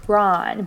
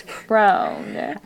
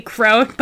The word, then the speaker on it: Kron: And I watched the movie with subtitles, so I always, in my head, said Kron. prawn, but it's prone. – I tried to change it in the notes so it's spelled out phonetically crone,